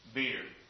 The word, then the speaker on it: bier: The King James uses the term beer, B-I-E-R, beer. That's how it's pronounced.